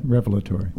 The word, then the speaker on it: revelatory: Wondrous, revelatory. How do you say that? – Revelatory. – Revelatory.